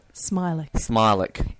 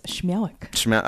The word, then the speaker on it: Śmiałek: Smilek. If you're Polish, it is Śmiałek.